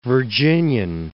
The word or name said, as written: Virginian